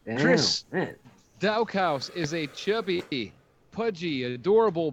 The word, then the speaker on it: Daukaus: Although I think it's pronounced Daukaus, if I remember, that's how they were pronouncing it. But I prefer to pronounce it like looks, Daukaus.